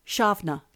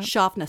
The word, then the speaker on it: Samhain: It is the season of, it's spelt Samhain, but it's actually pronounced in Celtic Irish, Shavna. – No idea what you're talking about. – Shavna, Samhain.